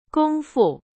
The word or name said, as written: 功夫